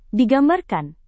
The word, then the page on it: digambarkan: Indonesian